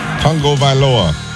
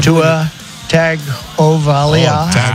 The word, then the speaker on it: Tagovailoa: Tagovailoa. – You got it? Come on. Come on, we believe in you. Come on. – Tua Tagovailoa.